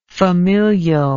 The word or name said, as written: familially